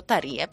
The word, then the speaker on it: Terje: Quando abbiamo chiesto a Nordgarden, ovviamente neanche il cognome si pronuncia in questo modo, Nordgarden, però quando gli abbiamo chiesto come si pronunciasse il suo nome ci ha detto Tarie, perfetto, abbiamo detto, noi siamo andati proprio, abbiamo centrato tutti gli accenti.